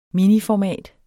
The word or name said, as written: miniformat